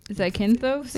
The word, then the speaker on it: Zakynthos: Well, so the, the, they also talked about so the city is um, as I think it's pronounced, is that Kithnos. Zakynthos. – Zakynthos. Yeah.